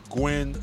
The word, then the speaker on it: Nguyen: Well, I was going to say if like the Vietnamese Gwen, N-G-U-Y-E-N, the N is silent. So you just say Gwen.